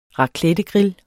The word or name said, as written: raclettegrill